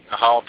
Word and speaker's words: Cathal: Cathal Pendred, I guess we know now that you don't pronounce the TH,